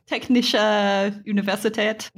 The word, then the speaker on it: Technische Universität: Am I pronouncing this correctly? – Sort of. Technische Universität. – Universität, Chemnitz. Thank you.